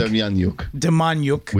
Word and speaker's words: Demjanjuk: So in the 80s, one of his constituents, I suppose, was a man named John Demjanjuk, which is spelled a lot different than you would think. – Demjanjuk. With J's.